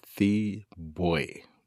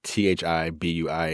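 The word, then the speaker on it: Thi Bui: It's called The Best We Could Do, an illustrated memoir by a writer by the name of, I believe it's pronounced Thee Bui, Thibui.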